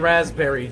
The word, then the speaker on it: raspberry: Don't make me pronounce the P in raspberry, Jake. Raspberry.